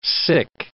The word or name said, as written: sic